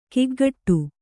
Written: ಕಿಗ್ಗಡೆ

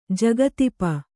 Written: ಜಗತಿಪ

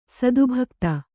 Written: ಸದ್ಭಕ್ತ

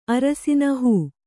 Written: ಹೋ

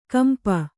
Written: ಕಂಪ

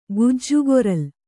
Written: ಗಿಜಗಾರ್ಲು

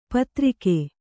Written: ಪೈತೃಕ

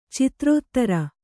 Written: ಚಿತ್ರೋತ್ತರ

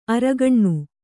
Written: ಆರಗಿನ

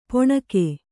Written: ಪೊಣಕೆ

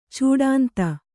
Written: ಚೂಡಾಂತ

